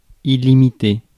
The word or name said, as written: absolute